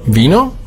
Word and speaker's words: Vino: Dann haben wir das V, wird ausgesprochen wie ein weiches B, Vino.